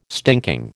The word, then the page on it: stinking: American English